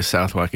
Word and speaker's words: Southwark: And to everyone that doesn't know, Southwark is actually pronounced Southwark. – Yeah, but it's spelled like Southwark. – It just literally says Southwark.